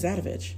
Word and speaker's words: Adovich: My last name is Adovich. – Yeah, but like, why is that? I don't. – Vich? – Like, Adovich? – It rhymes with bitch? – Yes.